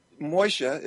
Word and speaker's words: Moshe: And before we had Moshe, is how we pronounce it, means Moses in Hebrew. Before we had Moshe, another family had him for 40 years.